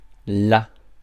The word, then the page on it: there: British English; [ðɛː]; American English; [ðɛɹ]; /ðɛə(ɹ)/; /ðɛː(ɹ)/